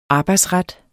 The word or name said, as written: arbejdsret